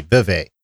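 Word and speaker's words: vévé: It's spelled V-E-V-E, Vevey.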